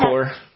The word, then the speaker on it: coir: I don't know how you pronounce it. – Yeah. – C-O-I-R. – Yeah, the core.